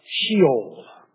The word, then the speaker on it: Sheol: It's explained in the Old Testament by the word, the Old Testament Hebrew word, Sheol, S-H-E-O-L, sometimes pronounced Sheol.